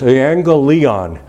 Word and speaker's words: euangelion: In the Greek, the word is actually, and I always have trouble pronouncing this in the Greek, I have enough trouble pronouncing things in English, euangelion, euangelion, from which we get evangel or evangelistic.